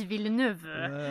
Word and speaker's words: Villeneuve: Denis Villeneuve, tror vi att det uttalas. – Ja, bra. Arrival. Denis Villeneuve. – Precis, Villeneuve.